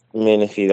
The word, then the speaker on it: Menifee: Menifee, California. Am I saying that right? – Yes, that's Menifee.